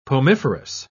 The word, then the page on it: pomiferous: British English